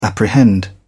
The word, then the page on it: apprehend: British English